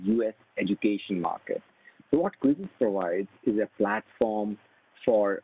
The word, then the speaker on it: Quizizz: Another company is a company called called Quizzes. It is spelled as Q-U-I-Z-I-Z-Z, Quizzes.